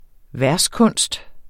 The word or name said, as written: verskunst